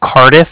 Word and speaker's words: Cardiff: Okay. – I'm calling with the Bank of Cardiff, and that's spelled C-A-R-D-I-F-F, as in Frank, Frank. So it's like car and then different.